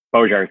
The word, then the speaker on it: Bojar: The way I think about it is, however you want to say it, you can say it. None of them bother me. In fact, I think it was Bojarski or Bojar when our family came over here. So I feel like we could have 20 pronunciations and whichever one works. Bojar is great.